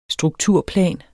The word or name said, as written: strukturplan